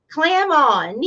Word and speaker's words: Clement: And I never heard it pronounced with a French twist until we moved up north to Massachusetts. They all wanted to put a French twist on it. But it's just clam on.